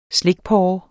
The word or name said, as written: slikporre